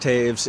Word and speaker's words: Toews: And even though it's pronounced Taves, it is T-O-E-W-S. – That's correct. Yes. If you're a hockey fan, it's like the hockey player Jonathan Taves.